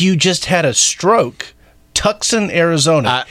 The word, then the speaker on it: Tucson: Well, would you imagine that Tucson is spelled with the C? – Yeah, it is weird.